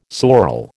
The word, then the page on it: sorel: American English